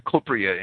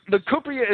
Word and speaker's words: Cypria: I'm going to read one paragraph from the Cypria, the Cypria, in according to most pronunciations, it's the Cypria in reality. The Cypria is an ancient poem, and it's part of the epic cycle.